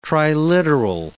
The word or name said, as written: triliteral